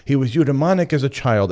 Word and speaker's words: eudaemonic: Eudaimonic. That's right. You heard me. Eudaimonic. E-U-D-A-E-M-O-N-I-C. Eudaimonic. I looked up not just the word, but its pronunciation. I'm pronouncing it correctly.